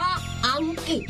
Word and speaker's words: อังกฤษ: วันหลังให้สังเกตให้ดีนะตัวรอเรือต้องออกเสียงเหมือนรอเรือและออกเสียงให้ชัดด้วยคำว่าอังกฤษออกเสียงกอควบรอด้วยนะ